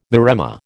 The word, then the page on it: maremma: American English